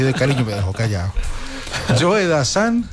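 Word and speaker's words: Joe Dassin: Y entonces presentó a Joe Dazan como si fuera Joe Dazin, como se escribe. Joe Dazin. Entonces yo le dije, no olvides de Anilla, que es Joe Dazan.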